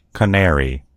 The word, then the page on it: canary: American English